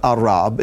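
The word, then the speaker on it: Arab (ארב): It's pronounced Arab. It's spelled slightly different. It's spelled with an Aleph instead of the Ayin. Aleph Resh Beit. And this word means to restrain.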